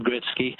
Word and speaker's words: Gretzky: Wayne Gretzky has always been pronounced Wayne Gretzky,